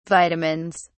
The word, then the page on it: vitamins: British English